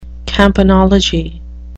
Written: campanology

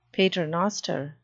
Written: paternoster

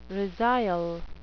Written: resile

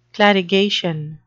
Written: clarigation

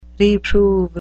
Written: reprove

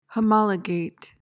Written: homologate

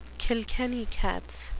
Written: Kilkenny cats

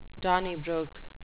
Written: donnybrook

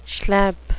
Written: schlep

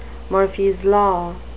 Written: Murphy's law

